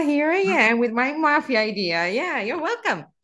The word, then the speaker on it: mafia: Just remember one F. It's not spelled with two F, but one. – So don't make that F a longer F sound. It's just supposed to be mafia.